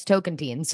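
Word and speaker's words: Tocantins: Actually, Jeff says token teens. You might be right, but I Googled the pronunciation and it's token teens.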